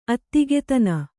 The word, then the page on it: ಅತ್ತಿಗೆತನ: attigetana